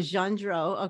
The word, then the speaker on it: Gendreau: Gendreau.